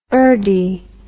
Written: urdee

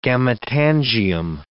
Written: gametangium

